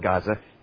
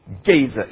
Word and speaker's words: Gaza: Verse 6, for three transgressions of Gaza, I'm sorry, not Gaza, Gaza is how you pronounce it.